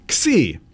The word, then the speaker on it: Xi: But then there's the other one, and it's pronounced Xi, but it's spelled X-I.